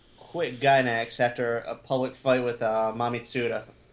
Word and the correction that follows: Masami Tsuda: Masami Tsuda.